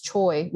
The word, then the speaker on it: Choi: But so in my case, it's Che, which is Choi, which is a whole nother thing because they Americanized it. So Che turned into Choi.